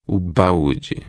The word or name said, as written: balde